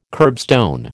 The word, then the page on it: kerbstone: American English